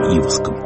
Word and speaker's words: Иваск: Владимир Варшавский в беседе с Юрием Иваском. Я делаю такое ударение в фамилии Иваска, потому что в науке об эмиграции так прижилось.